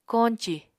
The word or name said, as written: koñcì